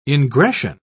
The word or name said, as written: ingression